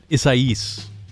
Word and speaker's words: Isaias: How is it pronounced? – Isaias, I think. – Nope. – Well, what? Tell me. – It's got a more of a kind of an accent to it. Isaias.